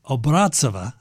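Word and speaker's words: Obraztsova: And the mezzo-soprano, in other words, the woman who plays Carmen, who sings this, is Elena Obrazova, or Obrazova is probably the correct pronunciation, accent on the second syllable.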